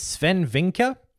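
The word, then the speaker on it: Swen Vincke: Also out of that, I think it's Sven Vinker is how you pronounce his name.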